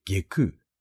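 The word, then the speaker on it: 外宮: さらに、外のお宮と書いて下空、内側のお宮と書いて内空とに分かれています。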